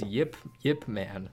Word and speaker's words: Ip Man: He chose for us to watch the Ip Man movies. Is that how you say it? – Ip Man, whatever. – Ip Man, because in the description it says Yip Man.